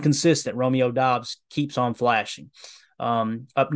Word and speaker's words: Doubs: We do have Doubs confirmed now from a, a tweet, and we really appreciate that. That is how you say Romeo's name. It is Doubs, but we still are in the in the dark about Enigbar, Nagbar.